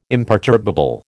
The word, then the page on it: imperturbable: American English